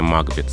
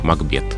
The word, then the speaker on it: Макбет: Но если мы имеем в виду историческое лицо, герой трагедии Шекспира, персонажа оперы Верди и сами названия этих произведений, то мы должны говорить «Макбет».